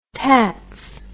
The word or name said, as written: tats